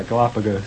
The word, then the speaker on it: Galapagos: Yeah, Galapagos.